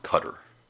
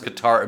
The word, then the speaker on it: Qatar: And I've been told it's pronounced Cutter.